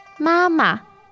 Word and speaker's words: 妈妈: 妈妈, 妈妈. – Again, the first ma syllable there is first tone. – Yes. – And then the second syllable is a neutral tone.